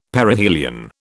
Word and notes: Perihelion: British English

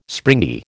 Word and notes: springy: American English

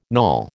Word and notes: knawel: American English

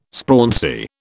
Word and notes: sprauncy: British English

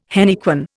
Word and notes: henequen: Indian

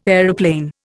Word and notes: aeroplane: Indian

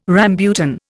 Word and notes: rambutan: Indian

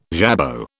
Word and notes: jabot: British English

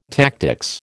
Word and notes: tactics: American English

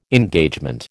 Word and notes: Engagement: American English

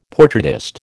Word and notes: portraitist: American English

